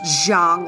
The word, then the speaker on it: Jiang: Now, I know it's pronounced Zhang, like Jordan or something, like, but I'm going to pronounce it funny.